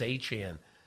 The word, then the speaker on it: Achane: They called him, and he said, yeah, it's Achane.